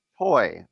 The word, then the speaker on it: Tsui: It is Bonnie Tsui, spelled T-S-U-I.